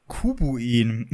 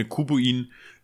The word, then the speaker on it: Kubuin: Hm, was meiner Meinung nach eigentlich auch eher in Richtung Digimon geht, ist Kubuin. – oh mein Gott, wie das schreibt man das? Ich, ja, K-U-B wie wie Cube halt, also ein Kubus, Würfel und dann U-I-N wie Pinguin.